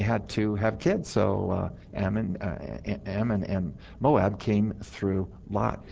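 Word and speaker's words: Amman: It's pronounced Ammon.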